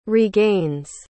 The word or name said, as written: regains